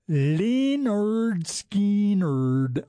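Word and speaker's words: Lynyrd Skynyrd: It is 10:40 and 20 seconds, and we just heard from Leonard Skinnerd, pronounced Leonard Skinnerd.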